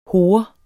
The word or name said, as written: hore